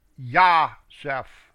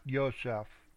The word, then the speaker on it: Yosef: It means, it's actually Yah-Seph. Not Yosef, but they spell it Yosef. Yah-Seph, actually. May Yahweh add to me another son.